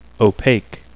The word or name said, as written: opaque